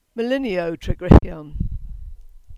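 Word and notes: Melinyow Tregryghyon: Cornish

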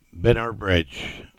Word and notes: Binner Bridge: English

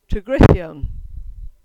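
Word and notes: Tregryghyon: Cornish

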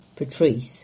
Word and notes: Portreath: English

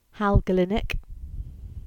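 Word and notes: Kelynack Moor: Cornish

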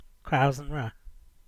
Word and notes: Crows-an-Wra: English

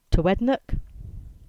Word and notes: Towednack: English

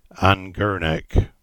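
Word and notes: Gurnick: Cornish

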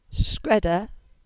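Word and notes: Skreda: Cornish

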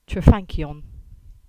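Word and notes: Crankan: Cornish

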